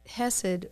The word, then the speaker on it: chesed: It's hesed. In English, you would spell it C-H-E-S-E-D. In Hebrew, it's chet. Samek, Dalit, and it means God's obligatory favor based on covenant, based upon the blood.